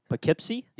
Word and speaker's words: Poughkeepsie: And speaking about Marist College, you just finished your third year there, and let me see if I get the pronunciation right. Poughkeepsie? – Yes. – All right, okay. – You got it.